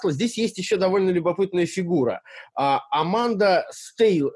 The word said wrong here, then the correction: Стейвли: э, Стейвли, да, правильно я Стейвли, произношу? Да, да, да, да. Стейвли, да.